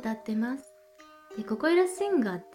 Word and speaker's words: 心夢響: 心に夢そして響くという漢字を書いてここゆらって読む